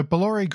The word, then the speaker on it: Bolloré: and they have bought Blue Point London from Bolliere. Is that how you pronounce it? – Bolloré.